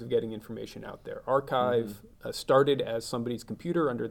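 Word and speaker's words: arXiv: In the old days, It's spelled archive is spelled with an X in the middle because that's Chi.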